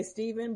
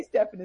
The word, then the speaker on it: Stephan: Yeah, because people usually say Stephen, but if you read it, there's an A at the end.